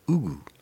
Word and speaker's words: Hugo: There's no H in Portuguese. No. So therefore it's Hugo, which Hugo sounds very odd. I think you actually silently pronounce the H.